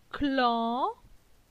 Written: Clan

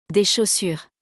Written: Des chaussures

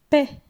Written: Paix

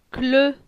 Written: Cle

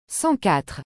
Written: 104

